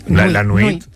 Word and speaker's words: nuit: La nuit.